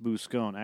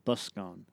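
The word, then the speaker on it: buscón: Pronounced Buscone. Actually, it's not pronounced that. It's pronounced Buscone, as in busker.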